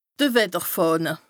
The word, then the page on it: de Wetterfàhne: Bas-Rhin; Strasbourg